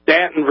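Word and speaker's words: Staunton: Eustace's address is Eustace Mullins, 126 Madison Place, Stanton, Virginia. And that's spelled not like it sounds, it's spelled S-T-A-U-N-T-O-N. S-T-A-U-N-T-O-N, Virginia.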